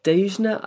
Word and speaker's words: Dhejne: Dejna, I think is how you say it. She's from Sweden, D H E J N E, and we'll put up the link.